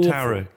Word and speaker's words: tarot: Tarot. Like carrot. – The T is silent, I think. – Arrot. – Tarot cards. – So it's arrot cards. – Tarot. No, there's a T at the end. – No, there's a T at the beginning. Tarot. – Tarot cards. – Tarot cards. – It's tarot. It's not an arrow.